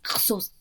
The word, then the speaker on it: Xhosa: But she sings in Xhosa as well. Sorry, I'm gonna say this properly. Xhosa. There's a click in there, she uses that language in her songs as well as English.